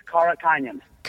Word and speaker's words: Karakhanyan: Am I saying that right? Karakhanyan? – Not really. – All right, tell me exactly how to say it. – It's Karakhanyan. – Karakhanyan.